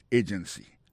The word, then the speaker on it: agency: Pour mot agency, hein, accent tombé sous premier syllabe là. Agency. Deux autres syllabes qui sont derrière eux, faible.